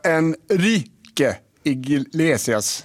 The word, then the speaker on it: Enrique Iglesias: Han var Enrique Iglesias, ja.